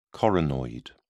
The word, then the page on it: coronoid: British English